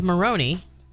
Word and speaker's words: Moroney: Am I saying it wrong? Moroney? – It's Maroney.